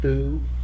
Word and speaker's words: through: Have you ever heard thoo? – Never. – We're thoo. Oh, it's thoo. It went through the window. It's pretty common.